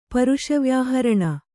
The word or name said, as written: ಪರುಷವ್ಯಾಹರಣ